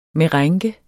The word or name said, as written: merengue